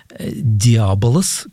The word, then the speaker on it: диаболос: «Диаболос», как мы знаем, так произносится греческое слово «дьявол».